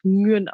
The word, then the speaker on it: Nguyen: Meistens auch so gesprochen, aber äh, wir sprechen ihn selber, zumindest im nordvietnamesischen Dialekt, Nguyen aus, wie Nürnberg quasi.